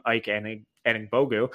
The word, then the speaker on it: Ike Anigbogu: He frequently got paired or aligned with UCLA center at the time, Ike Anigbogu.